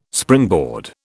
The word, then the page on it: springboard: British English